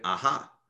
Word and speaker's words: Aja: Uh, the David Aja one in, did I pronounce that right? Aha. Uh-huh. You can say, uh, I think you can say both. David Aha.